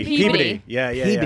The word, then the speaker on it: Peabody: It looks like Peabody, but it's Peabody. – But it's pronounced like garbage.